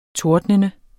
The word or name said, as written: tordnende